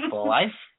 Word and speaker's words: Blythe: Blythe? B-L-Y-T-H-E? Blythe? Blythe? – Wait, spell that one more time. – B-L-Y-T-H-E.